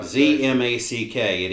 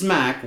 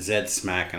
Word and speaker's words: ZMACK: Z-M-A-C-K. It is Smack, one syllable. You say Z-Mac, I'm fine. But we did have one British guy who kept calling it Zed Smack. And I'm like, I'm not going to tolerate that. So.